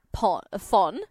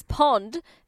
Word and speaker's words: Phon: His name is, I'm going to say, Fon. – Okay. – Spelled P-H-O-N. But his name is Pond, P-O-N, silent H. – What? – So Pond? – His name is Pond. Pon silent Pon. H.